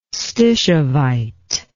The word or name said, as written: stishovite